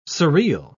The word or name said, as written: surreal